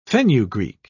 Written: fenugreek

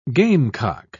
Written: gamecock

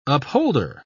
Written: upholder

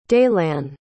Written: daylann

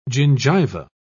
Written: gingiva

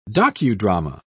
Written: docudrama

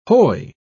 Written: hoy

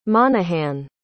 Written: monahan

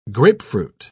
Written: grapefruit

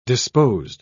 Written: disposed

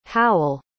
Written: howell

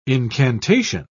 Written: incantation

